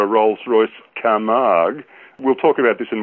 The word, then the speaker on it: Camargue: So it's the Rolls-Royce Camargue or Camargue? – Camargue.